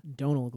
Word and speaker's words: Domhnall: Domhnall Gleeson, do you say? – Donal Gleeson. – Donald Gleeson? – Donal. – Donal? – Yeah. – Is that his name? – I'm like pretty sure that's how you pronounce it. You got to look it up. – I thought it was Domhnall. – No, it's not actually pronounced how it's spelled.